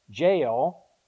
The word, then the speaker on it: Jael: Not J-A-I-L, J-A-E-L, but it's pronounced Jael.